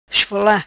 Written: Esfola

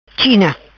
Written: China

